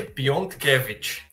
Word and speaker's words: Piontkewicz: É Piontkewicz.